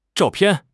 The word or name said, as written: zhao4 pian4